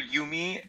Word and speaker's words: Jumi: Okay, so I'm I'm currently working for Yumi Cheese, um, which yeah, as as Diane as you said, it it looks like Jumi because it is spelled with a J, uh, but it's Swiss German.